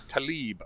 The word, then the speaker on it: Tlaib: censured Tlaib. Is that how you pronounce that? – Rashida Tlaib. – Tlaib. Okay.